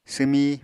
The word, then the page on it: swmi: apatani